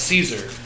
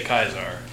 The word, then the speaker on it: Caesar: I think there's two pronunciations, that's why. – Well, I think Caesar is the only one who says Caesar. Everyone else in his legion will say Kaisar.